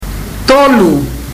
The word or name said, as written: tolu